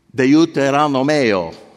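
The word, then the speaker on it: Deuteronomio: Genesis is Genesi, Esodo, Levitico, and then Deuteronomeo. It's a seven-syllable Deuteronomeo.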